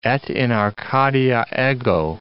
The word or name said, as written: et in Arcadia ego